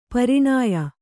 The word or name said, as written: ಪರಿಣಯ